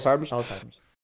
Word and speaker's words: Alzheimer's: Like, for example, let's say I was like 75 and I caught Alzheimer's. Am I pronouncing that right? Yes, Alzheimer's.